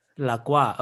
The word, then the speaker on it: La Croix: How do you pronounce the carbonated, like slightly barely flavored soda, like that is two words that are in French? – Oh, La Croix? – La Croix. Okay, you say that.